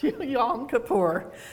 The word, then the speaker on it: Yom Kippur: I asked a Jewish man, how do you pronounce that holiday? And he said Kippur, Yom Kippur. And then I thought, Don said, that doesn't sound right. So I listened to three or four other Jewish people, and they said Kippur. So take your pick. It's Yom Kippur.